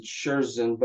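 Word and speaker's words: Schürzen: And if you have one of those fancy German vehicles, we've put a little marker on for I pronounce it Scherzen, but I'm not sure that's the right pronunciation. – Yeah, I think that is correct.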